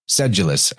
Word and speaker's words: sedulous: Sedulous, actually, is how you say it, which is somebody that's just persistent and putting out effort.